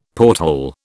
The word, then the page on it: porthole: British English